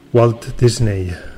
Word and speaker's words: Walt Disney: Sona de fons Walt Disney. No és Walt Disney el que tot el món coneix, es pronuncia igual, però el dis de Disney és THIS.